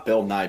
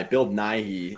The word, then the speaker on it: Nighy: Not Bill Nye. Bill Nighy.